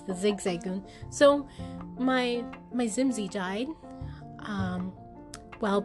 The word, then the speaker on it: Zigzagoon: That's proper way to say their name. Zigzagoon.